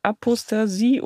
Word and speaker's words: Apostasie: Apostasie und Idolatrie? – Richtig.